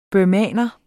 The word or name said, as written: burmeser